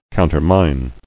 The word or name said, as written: Countermined